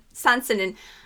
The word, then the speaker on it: Sanzenin: I listen to Sansanin, and am I pronouncing that correctly? – Yeah, I don't even pronounce it. Sansanin. – Okay, Sansanin. It's the opening track off of our natural world, and so it brings you into this different headspace, different musical space.